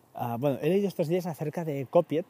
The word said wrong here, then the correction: Copit: Copit, perdón, Copit, eh, se, escribe Copit, se pronuncia, la aplicación ah, que es básicamente, pues está para, para Mac y para iOS, para iOS, para poder copiar entre portapapeles, ¿no?